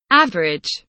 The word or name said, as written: average